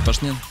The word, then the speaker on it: Пашнин: Максим Пашнин! – Мы не определились с ударением. – Определились, Пашнин.